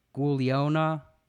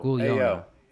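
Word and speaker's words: guaglione: The Nablodons have a word pronounced Gugliona. and there's a yo in the middle of it. Yona.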